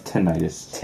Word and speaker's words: tinnitus: It's pronounced tinnitus. – Tinnitus? – Yeah. – I thought it was tentinitis. – It's not tentinitis.